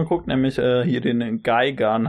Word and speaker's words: Gigan: Ich dachte meist Gigan, aber es ergibt Sinn, dass er Geigan heißt. Äh, ja, wäre halt englisch ausgesprochen.